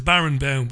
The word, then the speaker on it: Barenboim: I'm being told by my learned friend in Connemara that Barenboim, it must be pronounced Barenboim, but it's spelled Baron, B-O-I-M.